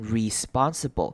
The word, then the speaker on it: responsible: 注意到 responsible 开头的 e 呢，我们会念成轻音的声音，所以不会，可能不会念成 responsible，会念成 responsible，念成一个的声音，轻音。